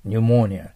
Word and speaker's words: pneumonia: His cold developed into pneumonia. New と読みます。 Pneumonia となります。